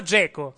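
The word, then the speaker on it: Džeko: Dzeko si pronuncia Dzeko, sì.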